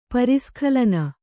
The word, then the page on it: ಪರಿಸ್ಖಲನ: Kannada